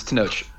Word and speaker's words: Tenoch: Yes, I listened to a pronunciation of it. It's Tenoch.